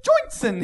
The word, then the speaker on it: Joynson: The UK's Home Secretary, William Joynson... – One more time. – William Joynson Hicks. I can only assume he's from New Jersey. I can only assume that. – Joynson. – It's Johnson with a Y.